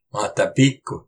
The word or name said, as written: Mátapiq